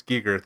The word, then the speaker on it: Giger: HR, is it Geiger? Giger? I think it's Giger they pronounce Giger.